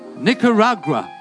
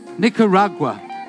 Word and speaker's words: Nicaragua: How do you say it? Nicker? What? I struggle with that word. Nicaragua. Nicaragua. Nicaragua.